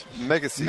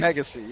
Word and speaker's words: Megyesi: I'm looking at the way it's spelled now, and that's not how it's pronounced. – Megacy. – Megacy, yeah. Megyesi is the way it's spelled.